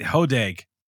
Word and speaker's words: Hodag: I Hodag. – Hodag. Hodag. Hodag. – Hodag. Hard G.